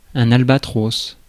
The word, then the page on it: albatros: [al.ba.tʁos]; France